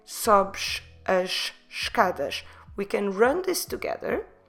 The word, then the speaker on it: escadas: E-S-C-A. D-A-S, escadas. And you see what happens here. Sobes ends at sh. As ends at sh. And escadas starts at sh.